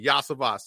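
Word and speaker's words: Iosivas: Listen, I heard like pronounced like four different times when I was. I'm pretty sure it's Andre Yosivas. I think the third time's a charm there. – Hey, you got it right, Yosivas.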